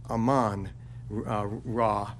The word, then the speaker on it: Amun-Ra: Interesting, in Hebrew, the syllable is almost always on the last. Excuse me, the accent is almost usually on the last. So it would be Aman Ra for us.